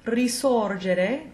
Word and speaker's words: risorgere: If you come across a word that starts with an RI as a prefix, the root word that follows will be pronounced the same way it would without the prefix. So in cases like risuonare, to sound again, risorgere, to rise again, and risoffiare, to blow again, the S will remain an unvoiced S.